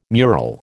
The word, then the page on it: mural: American English